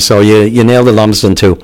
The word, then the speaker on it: Lumsden: Lumsden Beach.